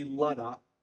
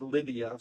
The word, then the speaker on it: Lydda: So the proper term would be Lydda. is how it's pronounced in the Hebrew. The English declares it as Lydia, so I may go back and forth.